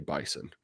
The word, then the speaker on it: Bison: You get to the bottom of it, producer, but I know you do pronounce it like a Z in the middle of it. People in the Dakotas get mad if you say Bison.